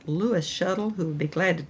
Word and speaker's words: Schoettle: I also would like to refer you to a wonderful publishing company, Shuttle Publishing Company, and that Shuttle is spelled S-C-H-O-E-T-T-L-E.